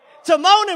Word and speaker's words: Timon: Pomona Tuma. What, how you say his name? Say it again, say it again. Timon and Pumbaa. I said Pumbaa and somebody else. Timon and Pumbaa, right? There you go.